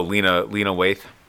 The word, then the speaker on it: Lena Waithe: Oh, Lena Waithe.